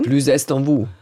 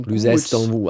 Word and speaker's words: plus est en vous: Ja, man spricht ja hinten dann die Konsonanten, spricht man ja hart aus, wenn ein Vokal dann wieder folgt. – Oh ja, okay. – Ach, du weißt aber auch nichts, wirklich. – Das hat ja Französisch. Also sag bitte, wie wird es ausgesprochen? – Plus est en vous, würde ich sagen. – Oh, plus est en vous. Also so gebunden wird das dann ja gesprochen.